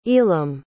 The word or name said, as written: elam